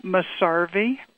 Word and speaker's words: Mesarvey: Darla Elaine Masarvi. – Spell it. Can you spell Masarvi for me? – Masarvi, M-E-S-A-R-V-E-Y.